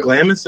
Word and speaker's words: Glamis: Glamis, did I say it right? – Yeah.